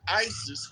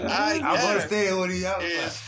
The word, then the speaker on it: Isis: And when they wake up, they call back Isis, Queen of Heaven and Earth. What's the first syllable of that name? The first syllable, not letter. – Is that ice.